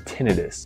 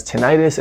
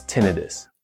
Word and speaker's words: tinnitus: So for that reason, the medical field pronounces it tinnitus. It's commonly pronounced as tinnitus, and that's okay. So what you need to know is that both pronunciations are acceptable, but there's one that's technically appropriate, and that's tinnitus.